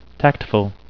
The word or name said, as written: tactful